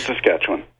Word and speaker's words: Saskatchewan: Saskatchewan? Sass and Sasquatch? What? Okay. I just go the other way. That's all I know. – Saskatoon, Saskatchewan.